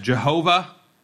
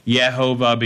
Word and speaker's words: Jehovah: That's not really right. There's no J sound in Hebrew. In the Middle English, J made the Y sound, and so Yehovah became Jehovah, the way that we spell it. But he gives him this name, which is modern scholars give the vowels as Yahweh instead.